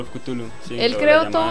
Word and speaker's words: Cthulhu: La llamada de Cultu, no sé cómo se dice, nunca supe. Ah, Cthulhu es.